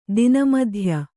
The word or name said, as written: ತನುಮಧ್ಯೆ